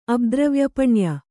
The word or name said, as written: ಅಬ್ದ್ರವ್ಯಪಣ್ಯ